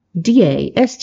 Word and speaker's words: Dastmalchian: Mollishin? Dastmalchian.